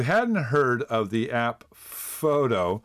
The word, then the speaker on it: Phhhoto: All right, I don't know how to say this. It's spelled P-H-H-H-O-T-O. So I'll just say photo.